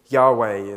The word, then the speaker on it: Yahweh: Yahweh is how some people think it might be uh, pronounced in Hebrew, the Lord.